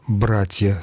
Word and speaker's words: братья: Она в просторечии действительно распространена, но литературно является форма «братья» с ударением на корне.